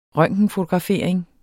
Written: røntgenfotografering

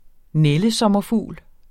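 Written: NÆLDESOMMERFUGLE

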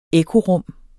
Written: ekkorum

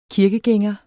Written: kirkegænger

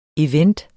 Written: event

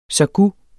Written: sågu

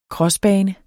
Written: crossbane

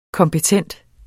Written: kompetent

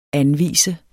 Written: anvise

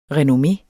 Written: renommé

